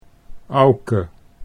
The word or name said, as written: Auke